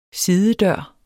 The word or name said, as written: sidedør